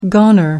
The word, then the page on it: goner: American English